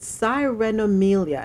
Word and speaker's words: sirenomelia: of our story comes out of Haiti, local and international experts follow the case of a baby with sirenomelia. And I hope I pronounced it right. I broke it down to syllables. That's S I R E N O M E L I A.